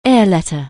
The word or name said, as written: air letter